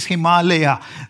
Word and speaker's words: Himalaya: But if you want to pronounce it right, it is not Himalaya, it is Himalaya.